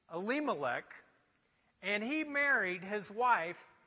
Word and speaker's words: Elimelech: It's pronounced Elimelech.